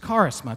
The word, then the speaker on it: charisma: The word there that's used in Greek for gift is the word charisma. It's pronounced charisma.